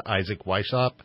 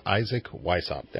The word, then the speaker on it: Isaac Weishaupt: Well, you are listening to Radio Wasteland, and when we come back, we have our guest, Isaac Weishaupt, and we will be discussing the Illuminati and occultism. All right, welcome back to Radio Wasteland, and we're here with our guest, Isaac Weishaupt.